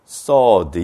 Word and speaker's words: sword: 조심해야 될 게 발음할 때 이거는 sword 이렇게 읽어야 되는 거지 sword하고 W 발음하면 안 되는데 이 많은 사람들이 이걸 sword라고 읽어요. 이건 잘못 읽는 발음이에요. 이 발음은 sword 하고서 그냥 sword 이렇게 읽어야 돼요.